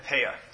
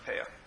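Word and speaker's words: peah (פאה): Shechacha, in English, I would say is S-H-I-C-H-E-C-H-A or something. That's how it's spelled in English. Shechacha, shechacha. And then peah is the third one, peah.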